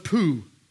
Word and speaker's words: Pu: After Abimelech, there arose to save Israel Tola, the son of Pu, that's how you say it in the Hebrew, son of Dudu.